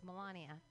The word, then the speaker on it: Melania: Melania. How do we pronounce her name? You guys know? Melania. It's Melania.